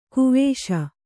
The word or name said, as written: ಗವಿಸು